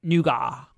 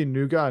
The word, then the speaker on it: Nougat: It's going to be called Nougat. – So you see Nougat and I see Nougat. – That's because that's how it's said.